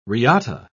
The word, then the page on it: riata: British English